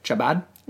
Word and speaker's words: Chabad: Chabad.